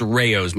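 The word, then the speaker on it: Rao's: And by the way, it's pronounced Rayos. My buddy from Arena DeVita says.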